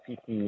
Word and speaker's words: Fithi: Yeah, Fifi, yes. – How do you spell that? – F-I-T-H-I. – F-I-H-I, Fihi. Fihi? – F-I-T-H-I.